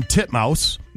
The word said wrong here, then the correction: Titmus: Titmus. – There you go. – Is that how you say it? – That is legitimately how you say it.